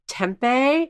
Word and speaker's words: tempeh: She didn't mention tempeh.